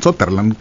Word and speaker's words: Sutherland: Sutherland, es correcto leerlo, digo, pronunciarlo como se escribe.